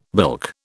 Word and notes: bilk: British English

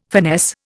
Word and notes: finesse: Indian

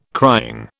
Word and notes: crying: British English

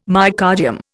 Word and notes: myocardium: Indian

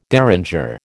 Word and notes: derringer: American English

